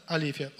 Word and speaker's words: алифия: А слово, которое переводится, вот те, которые делают правду, правду делают, да, это и есть, а, как оказывается, алифия. Ударение на первое «и». Алифия.